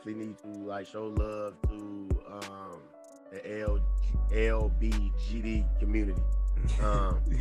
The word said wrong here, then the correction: LGBTQ: uh, I ah, did I say that wrong? – LGBTQ community.